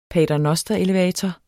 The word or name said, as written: paternosterelevator